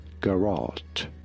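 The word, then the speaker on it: garrotte: I'm privileged like that, but in British English this is normally said as garrot.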